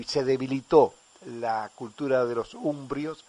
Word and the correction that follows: umbros: umbros, que así se pronuncia, la umbría en la región.